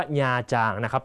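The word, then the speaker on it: Nha Trang: คำนี้ต้องอ่านให้ถูกนะฮะอ่านว่าญาจางนะ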